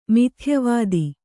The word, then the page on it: ಮಿಥ್ಯಾವಾದಿ: mithyāvādi